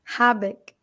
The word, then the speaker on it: Habeck: É, o como que, é o, como que se pronuncia o nome dele que vai liderar o Ministério da Economia, Energia e Clima? Habeck. Habeck.